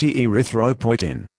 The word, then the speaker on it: erythropoietin: Epotin beta is also known as recombinant human erythropoietin, pronounced erythropoietin.